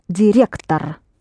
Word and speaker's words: директор: Директор. – Директор. – Yes, you do spell директор, but the О at the ending is not pronounced as О.